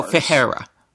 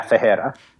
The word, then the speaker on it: Ferreira: Do you pronounce none of the R's as R's or one of them as R? – You pronounce the second batch as R's. – So Ferreira. – Yeah, Ferreira.